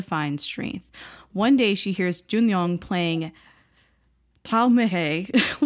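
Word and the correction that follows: Träumerei: which I think that's how you say it. It's in German, and I looked up the pronunciation, and I think I just butchered it anyways. But It's Traumere. – Traumere for the those who don't speak German like me.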